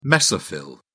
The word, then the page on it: mesophyll: British English